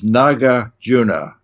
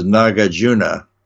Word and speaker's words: Nagarjuna: And Nagarjuna, who was really the name, I always mispronounce this, Nagarjuna. Hmm. The Nagas were the sea creatures that were his friends, and his other name was really Arjuna. Hmm. So he's known as Nagarjuna.